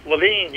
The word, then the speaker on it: Levine: Levine, you got it.